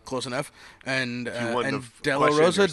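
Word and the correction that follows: Oloroso: Demarosa? – Olorosa. – Olorosa, fuck.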